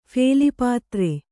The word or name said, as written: ಫೇಲಿಪಾತ್ರೆ